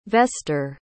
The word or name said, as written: vester